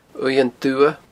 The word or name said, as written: uaimhean dubha